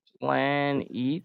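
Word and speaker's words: Planetes: Planeets? – It's spelled like planets, but has an ES at the end.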